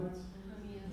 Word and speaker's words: Ananias: Now there was a disciple at Damascus named Ananias. Right? Am I saying that right? – Ananias. – Yeah.